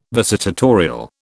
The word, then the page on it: visitatorial: British English